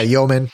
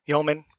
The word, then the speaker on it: Yeoman: And, you know, the cool thing was I built a project using, I think, Yeman's the pronouncer, Yo, right? So it has a template generator, goes out, generates a MVC application. – Yeah, Yeoman. – Yeoman. Yep.